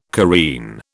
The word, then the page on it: careen: British English